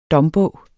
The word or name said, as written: dombog